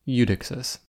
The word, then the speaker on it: Eudoxus: But I was mispronouncing his name the entire episode, like some sort of rube. In fact, his name is properly pronounced Eudoxus.